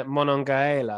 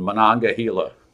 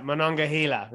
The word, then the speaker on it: Monongahela: and then Monongahela. I'm probably not pronouncing them very well. – You got them all but the last one, Monongahela. – Right, Monongahela, right, yes.